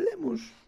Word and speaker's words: Lemos: De Monforte de Lemus Y, ¿Qué es eso? – Monforte de Lemos se llama el pueblo de ah, mi. Ah, de Lemos. Porque se pronuncia así, ah. Es galego.